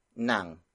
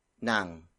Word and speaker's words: nang: The second word is nang. Nang. Nang. Nang is spelled as ng.